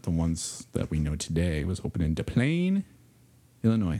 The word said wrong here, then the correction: Des Plaines: Is it DePlain? Am I saying that right? – I want to say it's Des Plaines. – Des Plaines? No. – That's I think people call it Des Plaines.